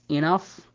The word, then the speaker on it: enough: أما أتى في نهاية الكلمة ينطق بحرف الفاء، ومثل ما أخذنا عليه الأمثلة كلمة enough كافي معناها، أو كاف كحة معناها، أو كلمة love اللي هي معناها يضحك.